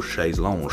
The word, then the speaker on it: Chaise Longue: Alongside the announcement of signing with Domino, they released their debut breakout single, Chaise Longue. I'm gonna say it like a French person every single time.